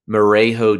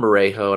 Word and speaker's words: Merejo: All right, I'm going to go to a little lesser known name, and that's Cleveland Guardians prospect Luis Marejo Jr., spelled M-E-R-E-J-O. So I hope I'm saying that right.